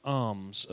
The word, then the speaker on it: alms: "Rather, give alms of such as things as you have, then indeed all things are clean for you." Now, alms isn't spelled A-R-M-S, give alms. It's spelled A-L-M-S, and that means a gift to the poor.